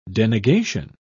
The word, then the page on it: denegation: British English